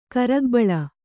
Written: ಕರಗಬಳ

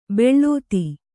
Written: ಬಿಳ್ತು

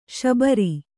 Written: ಶಾಬರ

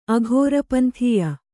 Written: ಅಘೋರಪಂಥೀಯ

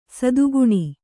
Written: ಸದ್ಗುಣಿ

